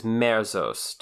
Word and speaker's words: merzost: And it is merost. Am I pronouncing that right? – The word is merzost.